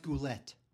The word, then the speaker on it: Goulet: Robert Goulet, right? Bernadette Peters. Come on. – It's pronounced Goulette.